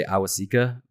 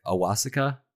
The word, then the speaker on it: Awosika: I always want to say Awasika, but Joe Joe Buck was calling it like Awosika, uh, so something like that, right? – Um, Ayahuasca.